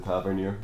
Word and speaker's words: Tavernier: Manages more crosses than Tavernier in any given game.